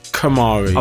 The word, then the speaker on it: Kamari: Hamari. Kamari. – Oh, Kamari, yeah. – Kamari, Hamari. Yeah. I'm not sure if you pronounce the K anymore. – Yes, Kamari. Obviously, okay, him.